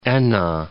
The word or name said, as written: Enna